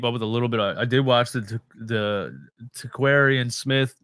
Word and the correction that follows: Tarquavion Smith: Am I saying his name right? – Tarquavion Smith.